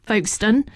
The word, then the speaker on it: Folkestone: I said that they would be stopping on their UK tour that kicks off at the end of this month at Folkestone. No, they won't be. I was promptly told it is pronounced Folkestone.